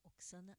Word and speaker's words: Oxana: Procurem o nome dela, Oxana com X, mas é, em português vocês, é, quem sabe, pronunciariam Oxana, mas é Oxana é, Oksana Elial.